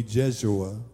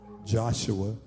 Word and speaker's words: Jeshua: And it reads, then the angel showed me Jeshua, or Joshua, as the Hebrews would pronounce, Joshua the high priest, standing before the angel of the Lord.